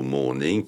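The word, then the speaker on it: mourning: et que c'est vrai que le mot « morning » en anglais se prononce de la même façon que l'autre mot « morning » qui s'écrit « M-O-U ». R-N-I-N-G, et qui signifie le deuil, la veillée funèbre.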